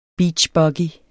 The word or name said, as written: beachbuggy